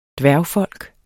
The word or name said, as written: dværgfolk